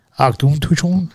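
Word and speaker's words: Auchentoshan: Here's how it's actually pronounced. Auchinchichon. – Auchinchichon? – Auchinchichon.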